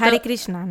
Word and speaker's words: Hare Krishna: Hare Krishna, ¿no? No estoy segura, no es que no sé si se diga así. – Pero ah, se escribe Hare. Yo siempre lo he escuchado Hare, Hare Krishna.